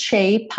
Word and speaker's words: shape: You're not here to see me in this shape, I Man. shape, I Man. Ok? shape, I. porque o shape termina com E, o E não é pronunciado, logo P é o último som dessa palavra.